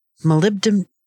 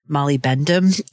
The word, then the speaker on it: molybdenum: molybdenum sulfide, copper, and iron oxide. I always used to pronounce molybdenum molybendum. So I always still have a little bit of trouble saying that.